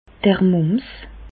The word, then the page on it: d'r Mùms: Bas Rhin; Reichshoffen